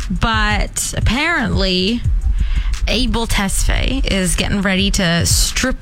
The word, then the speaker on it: Tesfaye: Tesfaye.